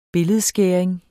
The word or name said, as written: billedskæring